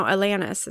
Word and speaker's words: Alanis: No, Alanis is how you pronounce it.